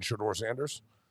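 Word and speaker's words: Shedeur Sanders: Shador Sanders.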